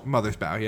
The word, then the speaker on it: Mothersbaugh: Is that how you say it? Bow? – Mothersbaugh, yeah.